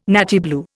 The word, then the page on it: nattier blue: Indian